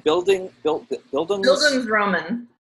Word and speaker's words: Bildungsroman: It's spelled B-I-L. D-U-N-G-S-R-O-M-A-N, Bildungsroman.